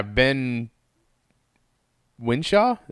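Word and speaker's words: Ben Whishaw: And yeah, Ben Winshaw, is that how you say his name? – Yeah.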